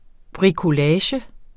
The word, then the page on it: bricolage: bʁikoˈlæːɕə; bʁikʌˈlæːɕ